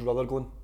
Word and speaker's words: Rutherglen: Am I pronouncing it correctly? Ruthglen, is that where you're from? – So where I was actually born in the hospital was Rutherglen.